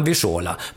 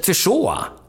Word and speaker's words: Wyszoła: Och det sa vi när det kom på telegram, Wyszola. Men han hette Wyszoła, inte Wyszola. Wyszola var stavningen, Wyszoła var uttalet.